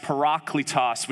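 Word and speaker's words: parakletos (παράκλητος): The Greek word advocate is a Greek word that's pronounced parakletos, which literally means a person who is summoned to someone's side to plead their case for them.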